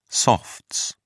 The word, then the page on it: softs: British English